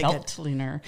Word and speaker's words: Veltliner: Was it Veltliner? – Okay, Veltliner.